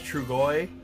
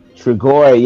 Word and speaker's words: Trugoy: It's Trugoy. – Trugoy, yes, yep.